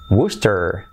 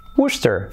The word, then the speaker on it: Worcester: Y que se pronuncia Worcester. Worcester. Fijaos, de Worcester, pues pasamos a Worcester. Así que ya para de decir, no digas Worcester, que es como yo lo hubiera dicho, y se dice Worcester.